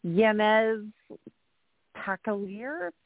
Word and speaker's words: Yanez Pacailler: Yanez was pretty good. It just depends on where you're from, if you're pronouncing it in the Spanish way or the American accent. And then it's Pacailler is how my husband's family says it, but actually in French is Pacailler.